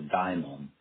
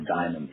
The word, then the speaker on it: daimon: And I know in your book, you talk about, I think it's Damon. Is that the proper pronunciation? – It's an old Greek word. It's called in their tradition the daimon, not the precious jewel, the diamond, but the diamond, D-A-I-M-O-N.